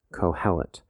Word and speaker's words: Qoheleth: The Hebrew name for the book is Kohelet. It's spelled Q-O-H-E-L-E-T-H, Kohelet, by Graham Ogden.